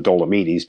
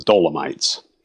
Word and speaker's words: Dolomites: I lived in Italy for 3 years, and I hiked in the Dolomites, and I pronounced them Dolomites because that's the way the Italians pronounce them. We would pronounce them the Dolomites.